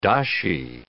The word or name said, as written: dashi